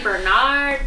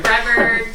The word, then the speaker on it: Brevard: Usually people say Bernard, Boulevard, and like... – Somewhere. – Brevard.